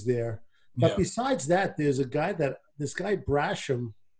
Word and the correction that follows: Basham: Is that how you pronounce his name? The linebacker? – Basham, I think. – Basham.